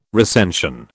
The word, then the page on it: recension: British English